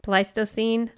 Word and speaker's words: Pleistocene: Archaeological sites on the island give interesting record of human interaction with the marine and terrestrial ecosystems, like from way back to Pleistocene.